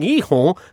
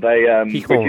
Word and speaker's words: Gijón: Gijón.